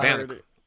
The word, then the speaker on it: Banneker: We call it Banneker. That's how you say it.